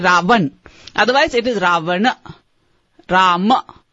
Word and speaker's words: Ravan: The reason we sometimes say Ravana or Rama is that in Sanskrit, unless you put a full stop at the end, it's sort of a something that closes out the vowel sound, the way you pronounce it in Sanskrit is Rama, Ravan.